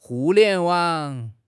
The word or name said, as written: Hu4 lian2 wang3